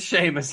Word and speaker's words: Seamus: It looks like Seamus. I'm pretty sure it's pronounced. – It is Seamus.